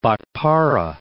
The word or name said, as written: Bhatpara